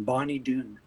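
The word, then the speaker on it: Bonny Doon: Well, it's spelled B-O-N-N-Y, Bonnie, with a Y, and another word, Dune, D-O-O-N, Bonnie Dune.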